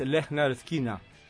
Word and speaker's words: Lynyrd Skynyrd: Ahí ponen cómo se pronuncia, pero se llama el pronouncer. – Claro, porque es una palabra difícil. – Es Lynyrd Skynyrd. Lynyrd Skynyrd. Ahí está fonéticamente puesto.